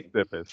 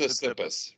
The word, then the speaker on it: Tsitsipas: Tsitsipas. – Yes, it's Tsitsipas. – It's pronounced Tsitsipas.